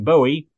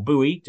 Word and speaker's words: Bowie: And of course, Jim Bowie, or Bowie, depending on the pronunciation that you actually take on that line.